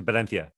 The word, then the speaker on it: Valencia: When we were down in the south, we went to Valencia. I remember that's how they said it. They didn't say va. They said it with a B at the beginning with a regional accent.